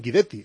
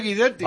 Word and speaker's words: Guidetti: Pero que te estoy diciendo que la pronunciación correcta de la pronunciación correcta del apellido es Guidetti, porque es un apellido porque es un apellido italiano. Es un apellido italiano. – Pero que él dice Guidetti.